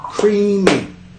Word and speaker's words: creamy: Tenemos cuántas sílabas en la palabra creamy. Creamy. Dos sílabas, ¿ya? Y el estrés cae en la primera.